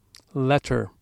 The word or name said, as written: letter